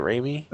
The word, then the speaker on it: Raimi: So Sam Raimi, that's how you say it, Raimi? – Uh-huh.